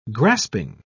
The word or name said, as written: grasping